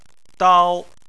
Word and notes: dao: 1

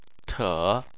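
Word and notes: te: tone 3

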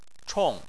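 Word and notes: chong: tone 4

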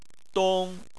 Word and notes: dong: 1

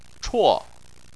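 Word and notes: chuo: tone 4

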